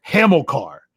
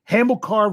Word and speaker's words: Hamilcar: Oh, I, I keep messing up the middle syllable of his name, so I'm going to try to get right. I'm pretty sure it's Hamilcar, Hamilcar Rashed.